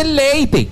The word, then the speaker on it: leite: No. Se dice leite.